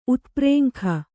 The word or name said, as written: ಉತ್ಪ್ರೇಂಖ